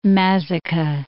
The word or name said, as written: Mazaca